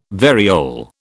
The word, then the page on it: variole: British English